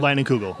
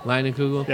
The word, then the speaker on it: Leinenkugel: Is how do you say that beer again? Leinenkugel. Line and Kugel.